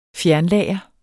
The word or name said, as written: fjernlager